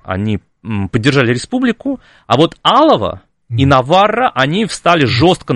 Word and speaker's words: Алава: И за это Наварра и Алава при франкизме, да, мы всегда говорим про что франкизм это такой унифицирующий, но Алава, прошу прощения, там ударение на первую букву,